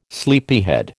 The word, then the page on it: sleepyhead: American English